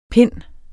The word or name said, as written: pinde